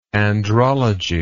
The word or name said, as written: andrologist